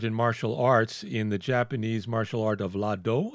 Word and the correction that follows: Iaido: Am I saying it right? – Iaido.